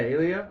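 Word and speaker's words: Ilija: But yeah, Ilya, I haven't changed the spelling. I'm from Macedonia, so the J is like a Y or a silent J sound.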